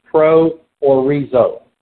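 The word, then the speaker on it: proorizo: This word predestination, pro or rizo, P-R-O-O-R-I-Z, long O.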